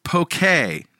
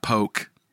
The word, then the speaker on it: poke: It's pronounced poke, not poke. – Poke? Tuna poke. – I said tuna poke. – That was your recipe from last week, right? – Yeah, yeah. No excuses. I didn't do my homework, and I took a waiter's bad pronunciation as definitive. But guess what? It was delicious. So, yes. Yeah, it's a Hawaiian word, poke.